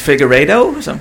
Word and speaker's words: Figueiredo: It's Figueiredo? – Figueiredo.